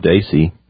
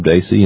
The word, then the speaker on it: Dacy: And you can go to Google Video and just type in Bob Dacey. My last name is spelled D-A-C-Y. And just type in Bob Dacey and Simple Truth.